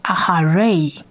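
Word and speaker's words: aha ray (אַחֲרֵי): 所以 r 加上 a 就是 ray ray ray，整个字的发音就变成 aha ray aha ray。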